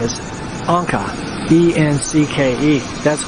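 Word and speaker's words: Encke: and it's E-N-C-K-E. I always called it Enki, but it's actually Anka,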